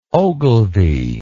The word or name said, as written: ogilvie